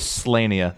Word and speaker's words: Slania: I'm going to confirm that it's pronounced the way that I think it is. It's not Slania. Oh, it is Slania.